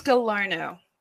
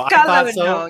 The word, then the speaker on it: Galarno: Oh, it's Galarno. – Galarno. – Yeah, it's Galarno, so. You know, it's French-Canadian. Yeah.